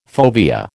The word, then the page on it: fovea: American English